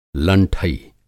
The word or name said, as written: लंठई